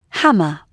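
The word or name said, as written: hammer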